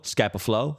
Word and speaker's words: Scapa Flow: Flow rhymes with go. Scapa Flow.